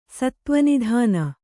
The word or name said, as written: ಸತ್ತ್ವನಿಧಾನ